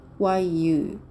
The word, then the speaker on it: 日语: 発音記号は RIYU 最初の文字が上から下に急降下する第 4 声